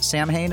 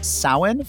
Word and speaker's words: Samhain: It's not pronounced Samhain. It's pronounced Samhain for the earth-based religionists among us.